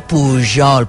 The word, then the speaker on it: Pujol: Pujol, Pujol, no, digueu Pujol, poseu la llengua aquí al paladar, no sé què, uns et deien, ah, vale, vale, ja està, però ella no.